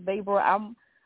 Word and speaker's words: Vrabel: Vrabel, did I say his name? Mike Vrabel, I guess it was it's D-R-A-B-E-L. I think you're saying it, we're saying it right.